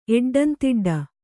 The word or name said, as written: ಎಡ್ಡಂತಿಡ್ಡ